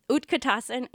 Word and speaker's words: Utkatasana: Now, for all of you out there, this is Utkatasana is a normal way of saying it. Saying any of these asana words by dropping the last A is a common thing. It's like a contraction of the word.